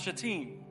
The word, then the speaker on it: Shittim: It's pronounced, it's Hebrew. It's pronounced Shatim. Filthy-minded people. So the Israelites set out from Shittim and went to the Jordan, where they camped before crossing over.